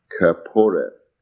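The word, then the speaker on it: Kapporeth: So the name of the covering, the name of the lid of the ark, is Kaporet, K-A-P-P-O-R-E-T-H, K-A-P-P-O-R-E-T-H. But this is from the word kephar. It's spelled different, pronounced a bit different, but it's actually the same root word meaning.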